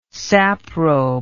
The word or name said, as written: saprobe